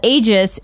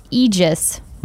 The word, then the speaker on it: Aegis: Kitten Tactics says what we called Aegis is pronounced Aegis.